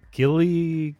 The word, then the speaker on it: Guillaume: Robert Guillaume. – Guillaume, uh-huh.